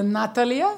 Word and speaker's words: Natalia: It's more on the Russian way, Natalia.